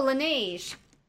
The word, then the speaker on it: Laneige: I also have this, but I don't know how to pronounce it, so. – Can you spell it? Oh, Laneige. – Yeah, they're sleeping. Mask is amazing. – Oh yeah, I love Laneige.